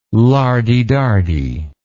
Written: lardy-dardy

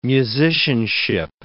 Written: musicianship

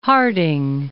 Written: Harding

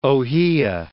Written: ohia

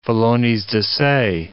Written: felones-de-se

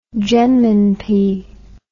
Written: jenminpi

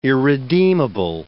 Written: irredeemable

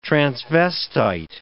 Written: transvestite

